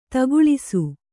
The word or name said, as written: ತಗುಳಿಸು